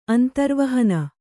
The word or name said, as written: ಅಂತರವಾಹಿನಿ